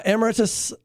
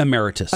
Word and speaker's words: emeritus: He was the most recently the head of the dicastery responsible for the appointment of bishops and the archbishop emeritus. How do you say that? – Emeritus. – Emeritus. Thank you. That's right.